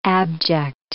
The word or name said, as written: abject